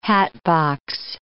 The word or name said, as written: hatbox